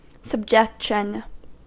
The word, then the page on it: subjection: American English